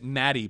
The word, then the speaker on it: Maty: M-A-T-Y, pronounce it Matty, please.